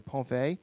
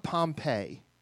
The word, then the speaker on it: Pompeii: They are getting prepared very shortly to be going to Pompeii. He tried to teach me how to say it. It's not Pompeii. It's Pompeii. Pompeii.